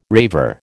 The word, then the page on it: raver: American English